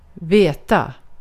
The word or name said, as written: haberi olmak